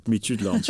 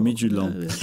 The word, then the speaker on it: Midtjylland: Zeg het nog één keer, jij zegt dat goed. – Midtjylland, ja, dat is eigenlijk heel makkelijk. – Eigenlijk zeggen dan de D op het einde, die wordt amper uitgesproken. – Oké, Midtjylland.